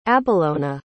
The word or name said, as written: abellona